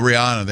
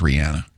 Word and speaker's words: Rihanna: And by the way, it's pronounced Rihanna. – Oh, excuse me. – Yeah. Pinky's up. She made it clear. That's the only reason I know. Because everybody says Rihanna.